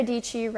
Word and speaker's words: Dietschy: My name is Sarah Dietschy, rhymes with peachy.